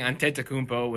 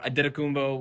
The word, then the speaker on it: Antetokounmpo: And then even then I was saying Antetokounmpo, which is more of the Greek, but I think yeah. the way he says it is more Adetokounmpo, which is truer to the initial, you know, pronunciation from Nigerian.